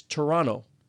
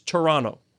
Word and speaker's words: Toronto: You don't say the second T. They'll look at you and say, you're not from here. – Well, how would you pronounce it? – It's Toronto, like two N's.